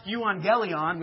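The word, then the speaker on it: euangelion (εὐαγγέλιον): That word gospel in the Greek is a word pronounced euangelion, which doesn't sound very much like evangelism or evangelize.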